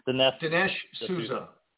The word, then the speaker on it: Dinesh D'Souza: Dinesh D'Souza.